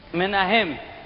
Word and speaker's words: Menahem: How do you pronounce that? Manion? – Menahem. – Very good.